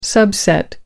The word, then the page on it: subset: American English